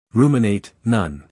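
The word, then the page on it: ruminate: uk /ˈruːmɪneɪt/; en-us /ˈruːmɪneɪt/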